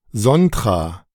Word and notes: Sontra: [ˈzɔntra]; German

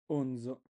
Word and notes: Onzo: Italian

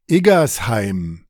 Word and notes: Igersheim: ドイツ語

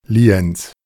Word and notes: Lienz: [ˈliːɛnt͡s]; German

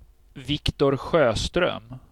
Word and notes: Viktor Sjöström: Swedish

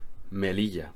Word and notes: Melilla: Spanish

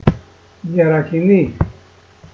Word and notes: Yerakini: Greek

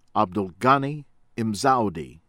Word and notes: Abdelghani Mzoudi: American English

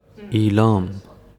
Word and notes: ایلام: Īlām; ペルシア語

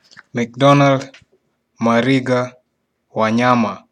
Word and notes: McDonald Mariga Wanyama: [məkˈdɒnʌld məˈriːɡɑ wɑˈɲɑːmɑ]

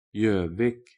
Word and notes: Gjovik: Norwegian

